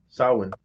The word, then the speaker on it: Samhain: They say Sam Hain, but it's actually pronounced Samhain. For everybody to know, it's pronounced Samhain.